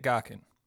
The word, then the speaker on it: Gaughan: But in order to do that, I need to tell you about Dick Gaughan. His last name is spelled G-A-U-G-H-A-N, and as I understand it, that middle syllable is supposed to be pronounced somewhere between Ock and Ach, like in Loch Ness.